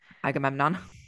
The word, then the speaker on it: Agamemnon: Agamemnon.